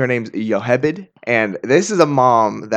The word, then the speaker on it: Jochebed: Our last mom that we're going to talk about today is, in English, we would pronounce her name Jochebed, which I believe more appropriately her name would have been Yochebed.